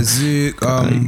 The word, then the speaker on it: Zouk: Yeah, Zouk, you got quite Kizomba, a few.